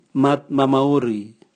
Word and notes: matmamauri: Raga

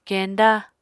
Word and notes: kenda: Apatani

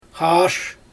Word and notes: xaaʃ: Xyzyl

